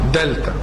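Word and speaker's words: دلتا (δέλτα): بيتنطق ازاي؟ زي حرف الدال بالظبط او زي حرف الدي. يبقى لو حطيناها كده قبل تتنطق ازاي؟ قول كده. دلتا نفس دي نفس دي.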